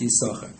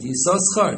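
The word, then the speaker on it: Yissachar: Supposed to be pronounced Yisachar. And others pronounce it Yisoschar.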